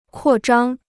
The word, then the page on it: 扩张: kuò zhāng